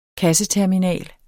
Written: kasseterminal

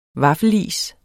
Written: vaffelis

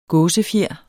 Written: gåsefjer

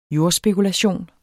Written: jordspekulation

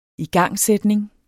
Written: igangsætning

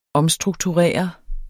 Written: omstrukturere

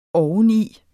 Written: oveni

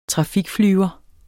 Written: trafikflyver

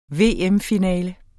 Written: VM-finale

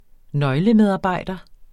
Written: nøglemedarbejder

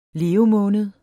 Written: levemåned

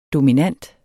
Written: DOMINANTE

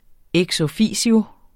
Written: EX

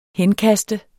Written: henkaste

